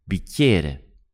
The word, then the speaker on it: bicchiere: This word bicchiere can be quite tricky to pronounce, but if you break it into three syllables, it's easy. So we've got bicchiere. The middle one is like yeah, the slang word for yes in English. So bicchiere, bicchiere, bicchiere.